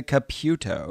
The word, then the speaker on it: Caputo: But, so it's C-A-P-U-T-O. I automatically pronounce that Caputo, but no. – She was introduced as Theresa Caputo.